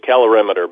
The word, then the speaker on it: calorimeter: So did I pronounce that correctly? – Well, it was calorimeter, but it's very close.